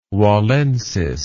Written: wallensis

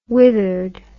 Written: withered

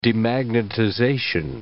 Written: demagnetization

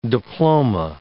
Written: diploma

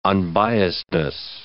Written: unbiasedness

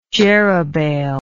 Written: jerubbaal

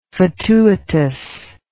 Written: fatuitous

